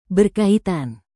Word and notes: berkaitan: Indonesian

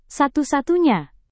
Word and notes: satu-satunya: indonesian

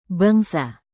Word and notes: bangsa: indonesian

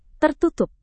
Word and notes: tertutup: Indonesian

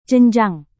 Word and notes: jenjang: Indonesian

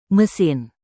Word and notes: mesin: Indonesian

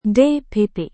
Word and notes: dpp: Indonesian

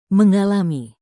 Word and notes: mengalami: indonesian

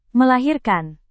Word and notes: melahirkan: Indonesian

